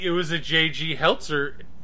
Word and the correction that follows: Hertzler: in... Hertzler? Impersonator. – Hertzler. – Yes, Hertzler. Oh. I've been pronouncing it wrong for ages.